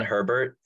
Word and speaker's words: Herbert: Is that Justin Herbert pronounces his last name just like the guy named Herb. – Herbert. – So it's Herbert. – Justin Herbert. The H is silent.